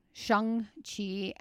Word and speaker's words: Shang-Chi: I have Shang-Chi. Did I say that right? – Shang-Chi and the Legends of the Ten Rings.